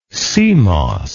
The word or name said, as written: cmos